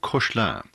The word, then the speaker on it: Koechlin: His name is Charles Cochelin. Last name is spelled K-O-E-C-H-L-I-N, of Alsatian descent, but born in Paris in 1867.